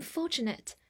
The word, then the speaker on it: fortunate: 我们来到第二十七天的学习，来看以下五个单词：fortunate，f o r t u n a t e，fortunate，前面 f o r 发 for，t u 的发音比较特殊，发 ch，n a t e 发 nit，fortunate，fortunate，这是一个形容词，表示幸运的、侥幸的。举个例子，I'm fortunate to have a good teacher。